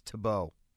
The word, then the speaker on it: Tebow: And by the way, I think you're mispronouncing. I think it's Tebow.